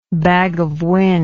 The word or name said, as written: bag of wind